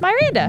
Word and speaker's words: Myranda: But then I had to teach everybody that my first name is Myranda. – Myranda, right. – Not Miranda. It is spelled Myranda.